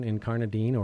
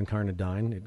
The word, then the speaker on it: incarnadine: Mary Shebist is our guest on the Jefferson Exchange, the author of the poetry collection Incarnadine or Incarnadine. It can be pronounced either way, which won the National Book Award for Poetry in 2013.